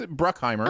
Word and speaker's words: Bruckheimer: It's Bruckheimer. – Oh, okay. okay. yeah, or, It's Bruck, it is Bruckheimer.